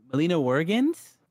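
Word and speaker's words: Melina Juergens: Melina, I hope I'm not saying this wrong. Melina Wurgens? – Nailed it.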